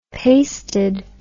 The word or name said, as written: pasted